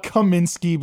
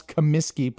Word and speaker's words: Comiskey: They used to call it Kaminsky because people can't pronounce Comiskey properly.